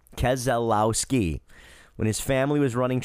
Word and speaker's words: Keselowski: Brian confirms Keselowski, all four syllables.